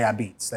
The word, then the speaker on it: apizza: So A-P-I-Z-Z-A, a beats. So think of the word or the phrase red beets, and then you could say a beets. That's how it's pronounced back eats.